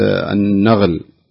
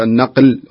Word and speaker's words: النقل: يعني بعض الناس يقول النغل الجماعي، يعني غالبا أهل الشرقية يقولون النغل الجماعي، فالمفروض تقول النقل وليس النغل.